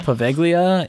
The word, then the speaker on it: Poveglia: Poveglia, yeah.